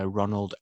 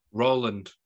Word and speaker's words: Roland: We'll talk real news, and our main review is box office bomb, Moonfall, directed by Ronald Emmerich. – Roland.